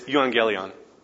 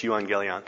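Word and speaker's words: euangelion (εὐαγγέλιον): It's euangelion. Okay, that's how you say it. It's euangelion.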